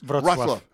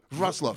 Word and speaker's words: Wrocław: So tell me how I'm doing this. – Wrocław. Wrocław. Wrocław.